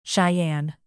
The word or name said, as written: cheyenne